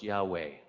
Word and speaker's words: Yahweh: Most scholars today have done all the research, and they believe that it is pronounced Yahweh.